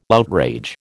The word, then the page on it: outrage: American English